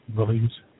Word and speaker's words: Belize: On this one here, and I can't pronounce the name of the country. Belize, Belize. – Belize.